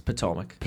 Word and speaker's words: Potomac: It's Potomac. – Potomac? – Yeah, like the river. – Well, that's how it's spelled? – W-E-K.